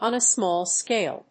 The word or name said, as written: on a small scale